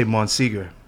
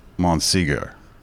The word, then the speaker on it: Monsegur: I don't speak French, so How do you say it? – I just say Monsegur. – Monsegur. Like Bob Seger? – Yeah.